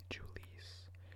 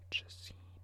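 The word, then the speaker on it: Jhoulys Chacín: Julius Chacin. Now this is an interesting name. J-H-O-U-L-Y-S. Julius. Then Chacin. C-H-A-C-I-N. Chacin. Julius Chacin.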